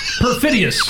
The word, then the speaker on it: perfidious: Let me correct you, my mispronouncing friend. You know, it's... Perfidious. – Oh, perfidious. – Perfidious. – Oh, yes. – Day. – Makes all the difference.